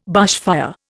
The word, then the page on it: bushfire: Indian